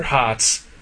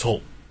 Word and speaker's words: Hotz: But, no, The one time that I met him at Mavericks, I am like 60% sure that when I called him Mr. Hotz, because his name is spelled H-O-T-Z. – He didn't turn around? – No, he looked at me and he's like, oh, it's Holt. – Huh. There's no L.